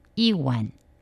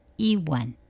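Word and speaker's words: 一碗: 중국어 표준어는 이완, 이완, 한 그릇이 되겠습니다.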